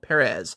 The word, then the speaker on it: Perez: George had a fanzine published in 1977 by Omnibus Publishing called Perez, Accent on the First E.